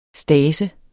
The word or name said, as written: stadse